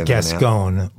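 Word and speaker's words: Gascón: Gascon. – I'm doing Rosetta Stone. – You're doing Rosetta Stone, and that. So you have the correct pronunciation, and the rest of America has it wrong? – Okay. I want you to get it. – From now on, I want everybody to say his name correctly, right?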